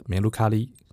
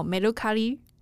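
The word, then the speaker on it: メルカリ: 现在的待的团队的 Mercari，诶，Mercari 是一间日本的独角兽电商公司嘛？你们家如果要用日文发音的话，怎么念比较正确啊？ – 嗯，就是梅鲁卡利。 – 哦，梅鲁卡利。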